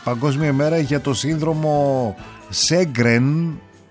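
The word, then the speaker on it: Sjögren: Πήρε το όνομά του, λέει, από τον Σουηδό οφθαλμίατρο Χένρικ Σέγκρεν. Στα σουηδικά προφέρεται Γέγκρεν.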